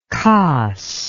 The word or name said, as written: kas